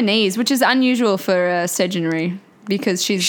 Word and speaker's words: Sedunary: S-E-D-U-N-A-R-Y. – Sedgenary. Yep, Sedgenary. Sedgenary is how you say it.